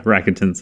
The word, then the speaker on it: Rakuten: Is it Rakuten? – Yeah, Rakuten. So yeah.